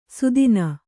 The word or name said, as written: ಸುದೀನ